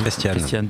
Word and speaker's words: Christiane: On est en train d'essayer d'avoir Christine Taubira. Christine ou Christiane, j'ai un doute. – Christiane, pardon, j'ai écorché son prénom.